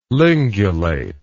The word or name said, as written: lingulate